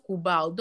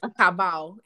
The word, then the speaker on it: cabal: Cabal, but it is pronounced Kubal. Don't go and disgrace me anyway. Go and say Kabal. It is Kubal.